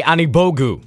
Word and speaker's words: Anigbogu: Leaf as well as the Pacers' second round pick Ike Anigbogu.